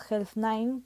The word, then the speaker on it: HELZ9: Su Instagram, lo voy a deletrear, es Health9, pero se escribe HELZ9. O sea, es el 9 de número, no es con letras 9, pone 9 y ya está.